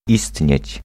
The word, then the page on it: olla: [ˈo̞lːɑˣ]; /ˈol.lɑ(ʔ)/